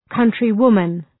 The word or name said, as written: countrywoman